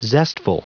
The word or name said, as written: zestful